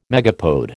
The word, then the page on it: megapode: American English